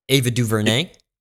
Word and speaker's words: Ava DuVernay: How did she pronounce her name? – Ava DuVernay.